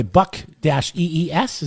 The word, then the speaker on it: Buc-ee's: And by the way, Buck-EES, is that right? – B-U-C-E-E-S. – Yes, B-U-C-E-E-S. Yeah.